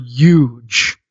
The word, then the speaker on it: huge: And your pronunciation of the word huge, there's no silent H in the word huge.